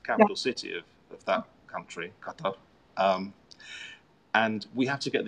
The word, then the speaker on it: Qatar: Like the locals say Qatar. – Okay. – I used to say Qatar like something in your nose, but now I say Qatar.